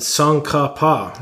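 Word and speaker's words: Tsongkhapa: Dr. Katoy is the author of Divine Contingency, Theories of Divine Embodiment in Maximus the Confessor and Song Ka Pa.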